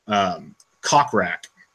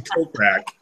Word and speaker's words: Kokrak: I am going with, and if people remember one of the first podcasts we did, I pronounced his name Cockrack. But Jason Kokrak is 35-1, and I'm going with Jason Kokrak to win the OHL.